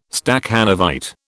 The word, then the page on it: Stakhanovite: British English